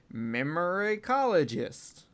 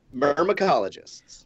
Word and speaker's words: myrmecologist: memoricologist. I didn't pronounce that right. How do you pronounce that? – Myrmecologists.